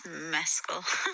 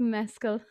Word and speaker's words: Mescal: One of them was right, and I can't remember which one, but she will tell us that it is pronounced. – It's Mescal. – It's Mescal. – It's Mescal. Thank you.